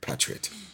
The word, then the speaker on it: patriotism: The short A, patriot.